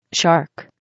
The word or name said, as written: shark